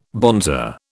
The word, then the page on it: bonzer: British English